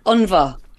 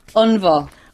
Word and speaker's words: Ionbhá: Unva. – Oh, Lord. I did a pronunciation check on the Google even, and it never came up with that variety. Say it again for me. – Unva. – Unva.